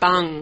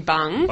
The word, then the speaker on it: Bung: A barg at Bung, because the restaurant pronunciation is actually Bung in Brunswick. – Like a bung leg.